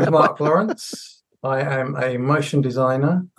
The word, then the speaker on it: Marc Lawrence: invite Marc Lawrence. Is that correctly said? Because I'm Danish, so I don't. Is that correctly said? – Did I pronounce it? Spot on. Spot on.